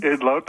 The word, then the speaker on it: Idlout: So MP Lori Idlout, is that how you say her name? – Idlout, that's correct.